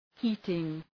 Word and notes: heating: ‘hi:tıŋ

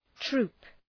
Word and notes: troop: tru:p

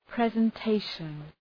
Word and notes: presentation: ,prezən’teıʃən

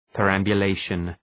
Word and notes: perambulation: pə,ræmbjʋ’leıʃən

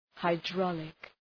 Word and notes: hydraulic: {haı’drɔ:lık}

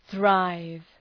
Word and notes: thrive: ɵraıv

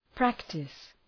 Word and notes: practise: ‘præktıs

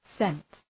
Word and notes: scent: sent

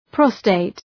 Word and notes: prostate: ‘prəʋsteıt

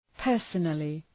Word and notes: personally: ‘pɜ:rsənəlı

